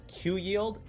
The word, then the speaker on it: QYLD: It's actually come to my attention that these Global X ETFs like QYLD and RYLD can actually be pronounced just like Q yield and R yield, which just blew my mind, and I'll probably be using them interchangeably throughout the video.